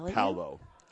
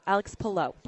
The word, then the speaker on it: Palou: Alex Palou. – Alex Palou.